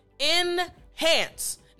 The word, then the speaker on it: enhance: Enhance. Now, the first part is very simple. I actually want to look at the last four letters. A-N-C-E. Ants. Ants. So, even though the letters are A-N-C-E, the sound is really A-N-S.